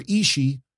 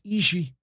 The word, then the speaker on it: Ishii: You know, not Tomohiro Ishii. It's Ishii.